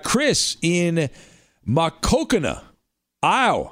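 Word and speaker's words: Maquoketa: I'm just going to keep saying that because Chris sent me how to properly pronounce it. So I think I'm saying it right. Makokana, Iowa.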